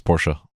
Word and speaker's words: Porsche: his Porsche, because it is pronounced Porsche. Don't don't argue with me. Don't argue with anybody because you'd be wrong.